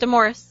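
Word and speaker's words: DeMaurice: But this Demora Smith character, that's the president, and I never say his name right, so it may be Demoris or whatever. – You got it, Demoris. – I got it. All right, thank you. The way it's spelled, there's a Y, so I always think Demoris.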